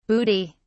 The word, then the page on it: bootie: British English